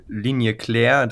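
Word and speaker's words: Ligne claire: Das ist ja die Linie Claire. Das, gut, alle Französisch-Kenner können mich jetzt korrigieren, wenn ich das falsch ausspreche. – Das klang ganz okay.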